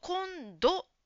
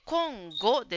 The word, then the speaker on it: 今後: 7 番の質問と答えに、今後という言葉がありますが、ジムさんは、今度と発音していました。今度ではなくて今後ですね。